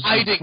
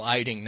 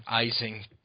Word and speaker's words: Eiding: It took me a while to find out who Paul Eisen was. I mean, not before. – Eiding, not Eisen. – My God, Donald, you can't even pronounce the dude's name. Paul Eiding, not Eising.